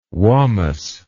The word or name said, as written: wammus